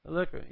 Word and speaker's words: Illyricum: and as far as Illyricum? Is that how you pronounce that? Illyricum, yeah.